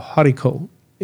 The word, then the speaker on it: haricot: It's called haricot. HARICOT. Mm-hmm. You don't pronounce the T in French.